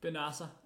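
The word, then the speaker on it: Bennacer: Den sidste, det er Ismail Benazer. Prøv lige at sige det igen. Ismail Benazer. – Øh, Fuck, hvor jeg håber, det ikke udtales sådan der. Ham ved jeg, Benasser. Benasser. Ismail Benasser. Nå, ham ved jeg, du har en holdning til.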